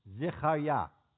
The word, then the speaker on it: Zechariah: Zechariah. That's how you really say his name.